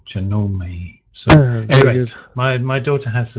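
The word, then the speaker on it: Janome: I was thrown by the fact that he pronounced the make of the sewing machine Genome, and I thought, that can't be right. No, you don't say the final E, do you? And I looked it up.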